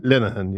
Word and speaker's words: Linehan: How am I pronouncing this? Linehan? – Linehan, yeah.